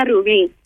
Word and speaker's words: Rubí: No, but it sounds better in Spanish. – Oh, it does? How you say it in Spanish? – Let's hear it. – Dígalo. – Sandra Ruby. – Say it again. – Sandra Rubí.